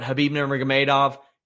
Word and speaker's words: Khabib Nurmagomedov: Oh, you when you hear the mainstream press accounts of this, listen to how they pronounce his name. If they pronounce his name as anything but Khabib Nurmagomedov, change the channel.